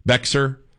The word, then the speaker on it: Bexar: I mean, I can read, but I got in trouble one time, too, because Bear County, Bexar, B-E-X-A-R. I mean, if you weren't from Texas, you wouldn't know how to say it.